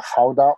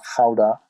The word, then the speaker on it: Gouda: So we started actually with the Gouda or the Gouda in the English pronunciation, but we call it in Holland Gouda.